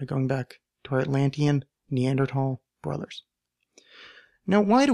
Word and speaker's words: Neanderthal: so that becomes tal, so Neanderthal, and when it goes through English, because there is, it's spelled Neander, T-H-A-L, because it's spelled that way, when it came through English, it became a th sound, so the English say Neanderthal, the correct, quote-unquote correct, would be Neanderthal, because that's where the original valley came from.